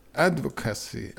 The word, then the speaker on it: advocacy: Och sen onsdag nästa vecka så är det lansering för årets advocacy, hur uttalar man det då? – Network. Advocacy. Network.